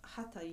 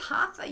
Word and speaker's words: hatha: Why are you pronouncing it like that? I thought it was hatha yoga. – Hatha yoga. – Hatha yoga is kind of the American way. Hatha is more of a Sanskrit way.